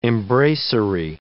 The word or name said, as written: embracery